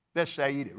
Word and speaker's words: Bethsaida: He took them and went aside privately into a desert place belonging to the city of Bethsaida. Bethsaida really is the way it's pronounced.